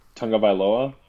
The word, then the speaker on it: Tagovailoa: Tagovailoa? – Yep.